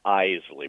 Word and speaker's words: Isley: The Isley Brothers. – Okay, all right. Well, hey, that's why we have corrections here, you know? It looks like Isley. It's pronounced Isley.